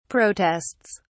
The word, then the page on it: protests: British English